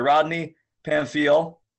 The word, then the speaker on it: Rodney Pamphile: Today, joined by Rodney Pamphile from the Zone Fitness facility out in Riverside, California.